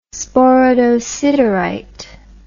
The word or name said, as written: sporadosiderite